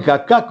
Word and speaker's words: Kaká: Sí, lo mismo que Kaká con K las dos veces. ¿eh? con, y con el acento en la última A.